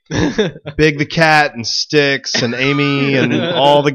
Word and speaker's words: Styx: Sonic has a new friend, and her name is Styx. Like the band, like the rock band Styx. – I think it's actually spelled like Styx. – It's just like Sticks and Stones.